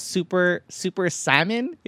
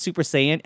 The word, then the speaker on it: Super Saiyan: And Ron, you could tell it's kind of bothering him a little that he keeps mispronouncing it because he loves anime. – Okay. – He's like, it's Super Saiyan,